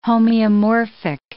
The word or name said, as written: homeomorphic